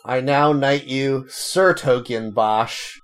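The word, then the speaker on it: 's-Hertogenbosch: Oh, no, sorry. It is *-Hertogenbosch. It's pronounced Sertogenbosch.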